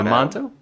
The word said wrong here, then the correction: D'Amato: Joe D'Amato? – D'Amato.